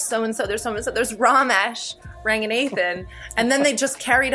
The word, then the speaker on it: Ranganathan: Ranganathan is not right. It's Ranganathan. – Oh. – That's the Sri Lankan pronunciation.